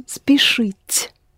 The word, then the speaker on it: спешить: The first letter is S, С, and in the end you have Ш. Спешить.